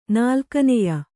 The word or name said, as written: ನಾಲ್ಕನೆಯ